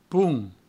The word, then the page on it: ponh: poung; provençal